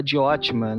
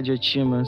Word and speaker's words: Diótima: Que é interessante você ver o amor sobre vários aspectos e acaba desembocando naquela narrativa da Diótima, né? Que é de Otima, sempre pronunciado. Errado.